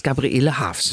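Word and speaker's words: Gabriele Haefs: Mit AE geschrieben. – Nee, AE. Also das ist ein Dehnungs-E. Das wird ganz oft falsch gesagt, aber sie heißt Gabriele Hafs.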